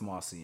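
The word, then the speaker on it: Moïse: President Jovenel Moise. I want to say his name is? – Moise. It's Moise, actually.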